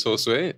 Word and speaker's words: Josue: I appreciate that because, you know, usually people try to say my name, it doesn't end well. But my name is Josue.